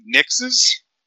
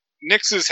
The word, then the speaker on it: Nixxes: So Sony has finally bought, I believe they're pronounced Nixes, N-I-X-X-E-S.